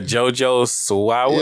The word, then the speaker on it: JoJo Siwa: that Disney star that came out, I can't pronounce, Jawa. – Yeah, JoJo Siwa.